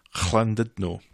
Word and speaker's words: Llandudno: And this is leaving the coast clear for Ches and Gemma to have their day out to Llandudno. – You pronounce that quite well.